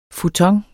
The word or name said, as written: futon